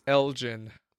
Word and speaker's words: Elgin: Oh, it's Elgin.